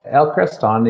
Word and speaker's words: El Creston: El Creston, you're correct, has been viewed as a molybdenum project, which really undersells what it is, and that it is a large deposit.